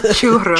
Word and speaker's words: churro: That's the Puerto Rican influence. – It's churro. Churro. Yes. You got to do HR. That's how you get that R sound.